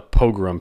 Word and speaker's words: pogrom: Now, it sounds like the word program, but it's actually a pogrom, P-O-G-R-O-M. And a